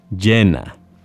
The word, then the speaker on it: llena: That's L-L-E-N-A. Two L's in Spanish are pronounced as a Y sound, ya, or a sh sound, or even a j sound. They are all perfectly acceptable ways of pronouncing the double L.